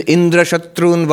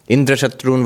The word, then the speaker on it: Indra Shatru: Now, enemy of Indra, Indra Shatru, the word, it can be pronounced in two ways, with accent on the first syllable or accent on the last syllable.